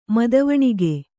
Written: ಮದವಣಿಗ

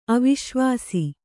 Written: ಅವಿಶ್ವಾಸ

